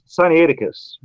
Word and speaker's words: Sinaiticus: It's supposed to be pronounced Codex Sinaiticus, but often we garble that one extra syllable.